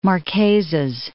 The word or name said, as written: Marquesas Islands